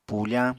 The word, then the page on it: pufañ: apatani